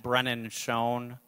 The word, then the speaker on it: Brennan: That's at Brennan Schoen.